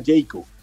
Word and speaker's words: Jaecoo: Las camionetas Jayco, usted las va a empezar a ver, eh, se escribe J-A-E-C-O-O,